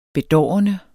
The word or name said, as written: bedårende